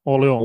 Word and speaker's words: Orléans: O-R-L-E-A-N-S. Och sedan en liten apostrof. – Jag hade nog inte sagt s bara. Orléans.